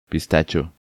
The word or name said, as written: Pistacho